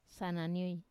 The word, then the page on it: sananioi: Wamesa